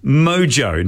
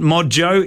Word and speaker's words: Modjo: It is Mojo, not Modjo, it's Mojo.